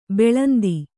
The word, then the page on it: ಬಾಲೇಂದು: bālēndu